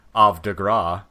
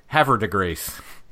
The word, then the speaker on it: Havre de Grace: For instance, there's a town in Maryland which has a French name, which pronounced in the correct French, I believe, would be Havre de Grace, but in Maryland we called it Havre de Grace.